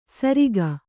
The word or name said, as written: ಸರಿಗ